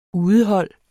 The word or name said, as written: udehold